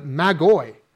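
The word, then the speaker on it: Magoi (μάγοι): You know, that's pretty much a transliteration of the word in the original language pronounced the Magoi.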